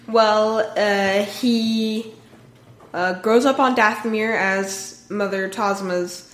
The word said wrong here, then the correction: Talzin: Talzin. – I can never get her name right.